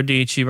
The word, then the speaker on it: Dici: Sarah Dici rhymes with peachy.